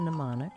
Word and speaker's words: mnemonic: How about, no, M is silent and mnemonic.